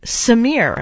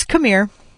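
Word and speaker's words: C'mere: And when I would play this track on the radio back in the very long ago days, I would pronounce the song Samir. I was like, oh, is this some French word I don't know about? Samir from Interpol. No, it's Camir. Camir. It's Camir from Interpol on Indie 102.3.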